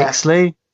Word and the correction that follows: Wickersley: Is that really? – Wickersley, yeah.